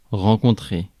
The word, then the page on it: rencontrer: France; /ʁɑ̃.kɔ̃.tʁe/